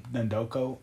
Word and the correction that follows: Ndefo: No, Defco. – Ndefo. Casey Ndefo.